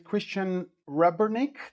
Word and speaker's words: Christian Rebernik: So today I'm speaking with Christian Rebernik.